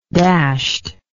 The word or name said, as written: dashedly